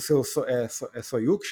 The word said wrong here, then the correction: soju: chama? Soju. – É, não sei como Soju. pronuncia, né? Escreve S-O-J-U.